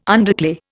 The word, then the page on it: underclay: Indian